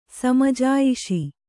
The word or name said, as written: ಸಮಜಾಯಿಷಿ